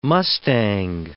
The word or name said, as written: mustang